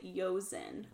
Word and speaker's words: Jozan: Okay, so it's spelled J-O-Z-A-N, and I looked this up right before, and it seems like you pronounce it Yozen.